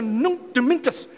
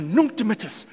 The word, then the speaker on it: Nunc Dimittis: We want to sing the Nunc Dimittis. Am I saying that right? No, the Nunc Dimittis.